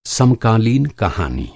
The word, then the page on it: contemporary: American English